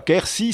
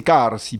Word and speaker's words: Quercy: La bonne prononciation, ce n'est pas Quercy, c'est Carsi, Pais de Carsi, c'est le nom occitan. Quercy étant une forme francisée.